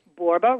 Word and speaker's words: Borba: I'm a 1L girl, so M-I-C-H-E-L-E. Borba rhymes with Zorba, so it's B-O-R-B-A.